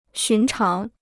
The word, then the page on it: 寻常: xún cháng